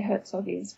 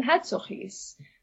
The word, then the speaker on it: Hertzoggies: So many people in South Africa would also say Hertzoggies. But if you're Afrikaans, and JBM Hertzog, the prime minister after whom they're named, was Afrikaans, then you would pronounce them Hertzoggies. But Hertzoggies is fine too.